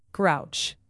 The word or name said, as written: grouch